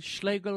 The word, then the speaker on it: Schlegel: Like, if you meet the queen, yes, your majesty, I'm Schlegel like bagel.